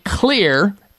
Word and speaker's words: Xlear: Spry is spelled S-P-R-Y, Spry Dental Defense System, or you can hit their website at Clear, X-L-E-A-R, the X stands for xylitol.